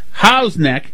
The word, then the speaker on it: Huff's Neck: 617 says Huff's neck is pronounced How's neck, or as the locals say, the neck.